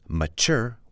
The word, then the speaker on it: mature: But the word mature, mature, we hear the emphasis on the second syllable.